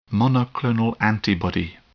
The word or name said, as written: monoclonal antibody